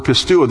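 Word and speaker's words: Pistuo (πιστεύω): And the W is a long O sound in the Greek. Pistuo.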